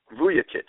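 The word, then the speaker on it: Vujačić: Vucevic. The J is a Y.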